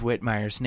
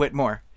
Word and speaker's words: Whitmire: On Twitter, it was mentioned that I've been saying Steve Whitmire's name wrong this whole time. I have had it in my head that his name is Steve Whitmore.